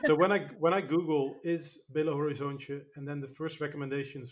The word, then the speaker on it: Belo Horizonte: Did I actually pronounce Belo Horizonte right or not? – 8 out of 10. That was good.